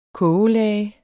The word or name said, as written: kogelage